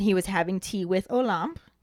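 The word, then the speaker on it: Olympe: Is apparently the proper pronunciation according to the YouTube video that I watched, because I was saying a limp or a limpy. I didn't really know how to say it.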